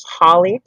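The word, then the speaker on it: Holli: I also have a direct email, which is Holly, spelled with an I. That's H-O-L-L-I.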